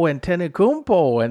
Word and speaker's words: Antetokounmpo: Oh, Antetokounmpo.